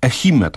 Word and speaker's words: Ehime: Otóż ogród zoologiczny w prefekturze Ehime, tak to się chyba wymawia. – Doskonale pan to wymówił.